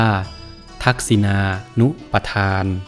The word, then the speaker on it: ทักษิณานุปทาน: ทักษินานุปทาน